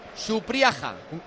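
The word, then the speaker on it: Supriaga (Супряга): La H ucrania se pronuncia G. – Ya me, he metido en el traductor ruso. Supriaga.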